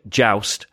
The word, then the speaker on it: Joost: Uh, although we subsequently learned that's not how you pronounce his name, which is a bit of a gutter, really. Uh, so sorry, J- Joust had a, a good game. Uh, so it's Joust, Joust isn't it? Joust.